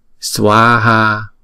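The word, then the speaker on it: svāhā: Swa ha. Sờ. Wờ. A. Swa ha là hai âm và mỗi âm là hai nhịp.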